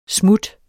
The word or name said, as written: smut